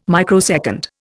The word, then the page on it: microsecond: Indian